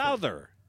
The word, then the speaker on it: Souther: Souther.